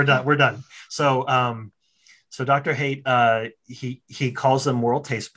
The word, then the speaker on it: Haidt: It's pronounced, by the way, it's pronounced Haidt, but it's spelled Haidt, H-A-I-D-T. That's how he pronounces it. So I'm pronouncing it the way he pronounces it.